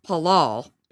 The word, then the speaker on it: palal: It comes from 6419, which is palal, P-A-L-A-L.